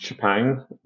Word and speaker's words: Chepang: As far as I know, it's pronounced Chapang.